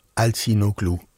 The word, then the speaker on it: Altinoglu: Lyon Operaens orkester spillede og blev dirigeret af Altinoglu. Og det er fransk orkester, fransk dirigent. – Og det var flot udtale.